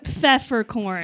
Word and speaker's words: Pfefferkorn: Pfefferkorn. – Pfefferkorn. Oh, I see. P-F-F-E-R. – Silent P.